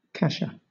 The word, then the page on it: kasha: British English